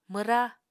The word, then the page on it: mwra: Apatani